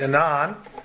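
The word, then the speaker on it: Aenon: Now, that A and E actually go together, which means the A is silent, the E is long, for that name of that city. So it's Enon,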